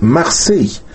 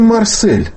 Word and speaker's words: Марсель: Нам нужно было бы писать «Марсей». «Марсей». Да, «С», «Е» и краткий. Но мы произносим «Марсель». И мы пишем «Марсель», и мы знаем «Марсель» как «Марсель», а не как «Марсей».